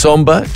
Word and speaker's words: Somba: Text Somba to 559. Somba is spelled S-O-M-B for Barry, R for Roger.